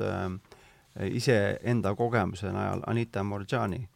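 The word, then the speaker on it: Moorjani: Äh, Anita Mor Jott Ani, Morjani Marjani. Äh, hääldatakse jah, aga J-ga käib see mm.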